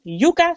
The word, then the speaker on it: Yuka: En zoon Yuka, zeg ik het goed zo? – Zeker. – Zeker. Yuka, die nu 10 maanden is, maar zoals je er dan bij zegt, 8 maanden gecorrigeerd.